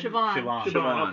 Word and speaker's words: Siobhan: S-I-O-B-H-A-N. – Siobhan. – Okay. – No, not a B, it's a V sound.